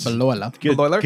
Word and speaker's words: Bleuler: The concept was coined in 1911 by a German psychiatrist, Eugen Bleuler. – Good. – Bleuler. – Good try there. – That's actually how you pronounce it. I speak a little bit of German.